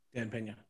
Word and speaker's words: Dan Pena: You, know, you guys had mentioned, um, I think hopefully I get his name right, Dan Pena. Dan Pena, yes.